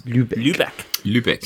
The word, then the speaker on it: Lübeck: Lübeck.